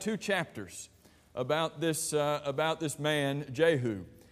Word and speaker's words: Jehu: it's properly pronounced Jehu. I think we usually say Jehu, so I'll probably say both as a part of this class to make all of us feel good.